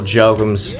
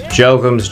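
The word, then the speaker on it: Jochums: Oh, MJ Comic on Instagram and Twitter, and you can find me on Facebook at Michael Jokums. Jokums, J-O-C-H, is not a stage name, it's just unfortunate.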